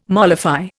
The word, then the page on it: mollify: Indian